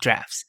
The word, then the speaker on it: draughts: Drafts, which is spelled D-R-A-U-G-H-T-S and can obviously be misspoken.